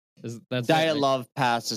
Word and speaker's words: Dyatlov Pass: Dyatlov Pass is how you're supposed to say it.